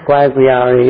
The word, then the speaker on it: Guayaguayare: Guanaguana, is that right? I'm trying to remember how to pronounce some of the names. – I don't know. Guayaguayare. It's hard to pronounce. Guayaguayare, yes. It's further south than Mayaro that I spoke about.